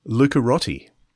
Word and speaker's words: Lucarotti: So this is the remaking of John Lucarotti? Is that how you pronounce his name? – Lucarotti. – Lucarotti.